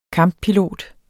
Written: kamppilot

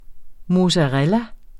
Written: mozzarella

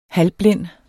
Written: halvblind

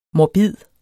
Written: morbid

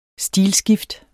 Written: stilskift

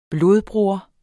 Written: blodbroder

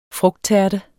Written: frugttærte

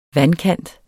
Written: vandkant

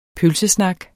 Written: pølsesnak

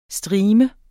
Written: streamer

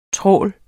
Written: trawl

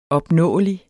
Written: opnåelig

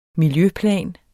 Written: miljøplan

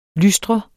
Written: lystre

